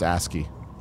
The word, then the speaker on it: ASCII: Albrecht says it's pronounced ASCII. A-S-C-I-I.